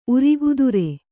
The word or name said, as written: ಉರಗಧರ